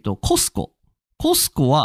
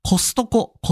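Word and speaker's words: Costco: あ、ちなみに、参考までに、コスコは日本では、なんと、なんだっけな、コストコって言われてます。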